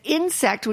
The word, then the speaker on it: Ynsect: And since we're talking fish today, I thought I would include the news that Insect, which is spelled Y-N-S-E-C-T,